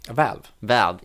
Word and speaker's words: Valve: Hur uttalar man det? – Valve. – Jo, men på med svensk. Ja, men okej, vi säger Valve.